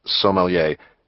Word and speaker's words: sommelier: The wine guy, whatever he's called, it's actually pronounced sommelier, he's your friend too.